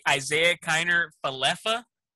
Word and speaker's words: Isaiah Kiner-Falefa: Isaiah Kiner-Falefa? – That's it.